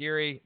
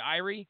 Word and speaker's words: Erie: I believe it is pronounced Erie, by the way. I thought it would be Irie. Isn't that like a Jamaican term? But they were referring it to as Erie in the video I watched.